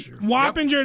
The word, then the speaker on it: Wappinger: Wappinger Falls. Am I saying that right? – Wappinger, yep.